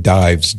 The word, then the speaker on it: Dives: And Dives, if you see that name, it looks like Dives, D-I-V-E-S is pronounced Dives. It's a Latin word.